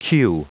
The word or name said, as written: cue